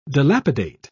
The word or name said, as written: dilapidate